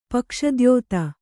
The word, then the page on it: ಪಕ್ಷದ್ಯೋತ: pakṣa dyōta